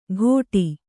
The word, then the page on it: ಘೋಟಿ: Kannada